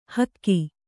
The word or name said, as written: ಹಿಕ್ಕೆ